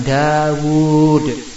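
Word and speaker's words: Dawud (داود): Itu tulisannya dal, alif, wawu, kemudian dal. Nah, tapi dibacanya antara wawu dan dal itu ada wawu lagi. Dawud. Wawunya dibaca panjang, Dawud.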